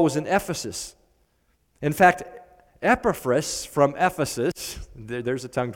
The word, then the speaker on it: Epaphras: Now, if I sometimes accidentally switch to the name, I kind of grew up pronouncing this name Epaphras. That's another possible pronunciation, but probably the better Greek pronunciation is Epaphras.